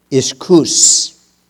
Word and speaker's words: ischus: Now, the word might here in the Greek is the Greek word iskous. Iskous. I like that one. Iskous. Yeah. It's spelled I-S-C-H-U-S, but it's pronounced I-S-K-H-O-O-S. Iskous.